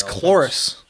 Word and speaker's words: Cloris: Gloris Leachum is not. – That's Cloris. – That's Cloris, though, man.